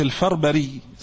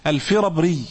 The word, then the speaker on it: الفربري: من أعيانهم يعني من أشهرهم مسلم بن الحجاج والترمذي والنسائي وابن خزيمة وابن داود ومحمد بن يوسف الفربري، مش الفربري زي ما بعض الناس بينطقوها يعني، الفربري، وإبراهيم بن معقل النسفي وحماد بن شاكر النسوي ومنصور بن محمد البرزودي.